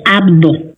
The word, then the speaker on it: Abdo: Y fíjate que ahora precisamente el director del deporte, como es, a ver si lo puedo pronunciar, José Abado, ¿es correcto? – Bueno, José Abdo. – Abdo, con la B, Abdo.